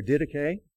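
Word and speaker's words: didache (διδαχή): And I put the pronunciation here, didache.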